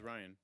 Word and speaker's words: Rian: Is that how you pronounce it? – Yeah, it's just Ryan. – Mark, you were saying something different yesterday. How do you say his name? – I thought it was like Rian Johnson. – No, nah, it's Ryan.